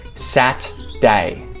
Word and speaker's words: Saturday: And so in Australian English too, you'll often hear people say Saturday. They'll reduce it down to just Sat-day. Sat-day. So instead of having one day of the week that has three syllables, Saturday, every single day of the week has two syllables.